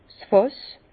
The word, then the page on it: 's Fàss: Bas-Rhin; Herrlisheim